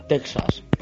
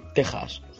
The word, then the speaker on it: Texas: Castaño, no es por parecer puntilloso, pero no digas Texas, por favor, di Texas. Esa X viene de la letra griega G, que se escribía como una X, pero se pronunciaba como una J.